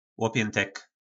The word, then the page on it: lopjyntek: po nasimu